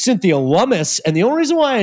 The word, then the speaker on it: Lummis: did a rhymes with hummus and brought out hummus, which was an incredible side story about Cynthia Lummis.